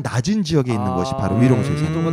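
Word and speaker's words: 玉龙雪山: 그곳에는 그 옥룡설산, 한국어 발음이 옥룡설산인데 중국어 발음이 위롱쇠산이라고 합니다. – 아, 이름도 잘 지어. – 네, 위롱쇠산.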